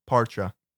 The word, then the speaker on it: parcha: Or parcha. – Parcha. – Yes. – You gotta roll the R. You could roll your R's, parcha.